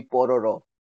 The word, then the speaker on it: Pororo: If I say it with a Korean pronunciation, it'd be Pororo.